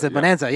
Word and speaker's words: Bonanza: Bonanza, yeah.